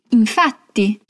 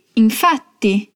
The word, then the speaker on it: infatti: Ela começa com sì, infatti. Aqui essa palavrinha è usata per confermare a informação anterior. E leva dois T's. Então capricha na pronúncia. Ripeti: sì, infatti. Sì, infatti.